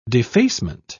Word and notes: defacement: British English

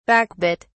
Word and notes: backbit: British English

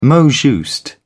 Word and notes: mot juste: British English

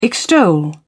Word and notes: extol: British English

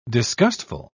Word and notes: disgustful: British English

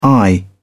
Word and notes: i.q: British English

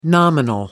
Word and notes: nominal: American English

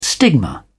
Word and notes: stigma: British English

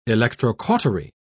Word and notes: electrocautery: British English

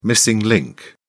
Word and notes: missing link: British English

